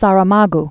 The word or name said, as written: Saramago